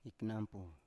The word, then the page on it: iknampu: Biak